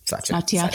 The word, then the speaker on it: Satya: I watched a video with Satya. Is that how we say his name? – Satya.